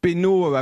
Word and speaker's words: Pesnot: P-E-S-N-O-T, que vous prononcez certainement Penaud également.